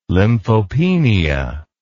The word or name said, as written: lymphopenia